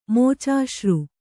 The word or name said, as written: ಮೋಚಾಶ್ರು